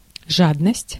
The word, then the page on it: жадность: /ˈʐadnəsʲtʲ/; /’ʐad.nəsʲtʲ/